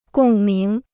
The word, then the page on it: 共鸣: gòng míng